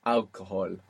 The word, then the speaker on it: alcohol: Alcohol. Good, because we don't pronounce H in Spanish, do we?